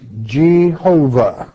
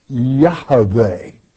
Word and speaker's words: Yahweh: For example, many would say Jehovah. Well, that's incorrect. It's Yahweh, to be properly pronounced and spelled from the Hebrew manuscripts.